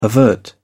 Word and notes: avert: British English

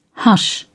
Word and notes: hush: British English

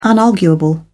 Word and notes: unarguable: British English

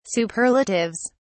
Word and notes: superlatives: British English

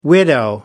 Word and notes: widow: American English

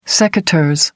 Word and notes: secateurs: American English

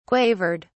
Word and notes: quavered: British English

